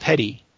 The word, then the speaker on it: petit: The word is actually P-E-T-I-T, pronounced petty, and it's sort of the complement to the grand jury.